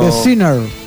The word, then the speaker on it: The Sinner: The Singer. Sí, no sé si lo dije bien, pero... – The Sinner. S-I-N-N-E-R.